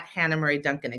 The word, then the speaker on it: Hannah Murray Duncan: But then you can also check out my Instagram is at Hannah Murray Duncan, exactly like my name is spelled out.